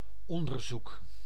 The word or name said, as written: research